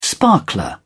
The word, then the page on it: sparkler: British English